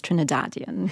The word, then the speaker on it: Trinidadian: But yes, The correct, you have said it correctly, it's Trinidadian.